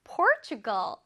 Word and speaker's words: Portugal: Portugal. Portugal가 어느 나라냐면 포르투갈입니다. 포르투갈. 포르투갈을 바로 Portugal라고 발음을 해요. 그쵸?